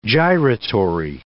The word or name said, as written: gyratory